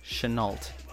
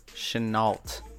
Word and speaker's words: Shenault: It is S-H-E-N-A-U-L-T. Chenault. – Wait. – I don't know. Was that right, Chris? – Who has him on the squad? – What was it again? Could you re-spell it? – S-H-E-N-A-U-L-T. Chenault. – You got it right.